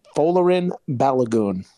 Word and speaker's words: Folarin Balogun: Folarin Balogun.